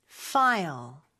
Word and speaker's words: file: 파일이 아니라 영어로는 F로 발음해 주셔야 돼요. File. File. 이렇게요.